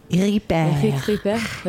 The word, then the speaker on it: Ripert: Now, did you pronounce that right? – Absolutely. – Eric Ripert. – Like repairman.